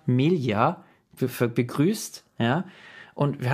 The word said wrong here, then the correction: Melia: Und äh, sie heißt Melia. Und das hat sie mir dann geschrieben, hat gemeint, das ist ja nett, dass du mich gegrüßt hast, aber die Betonung liegt auf dem I.